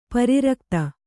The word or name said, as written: ಪರಿರಕ್ತ